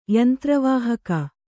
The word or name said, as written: ಯಂತ್ರವಾಹಕ